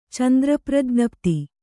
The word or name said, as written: ಚಂದ್ರಪ್ರಜ್ಞಪ್ತಿ